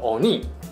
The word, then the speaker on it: 鬼: 其实啊，在日本文化相关作品中，鬼是相当常见的元素。《鬼灭之刃》里的鬼呢，日文读作 “Oni”，虽然汉字写成我们常见的鬼。但实际上呢，日本的欧尼跟中国还有西方的鬼是不太一样的哦。